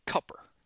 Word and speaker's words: Cooper: Is there a, you know, the annoying, one of the annoying things about that is that they pronounce it Cupper. And that really bothers me. It's like, the name is, it has two O's in it. It's Cooper. I know you have weird drawls down there.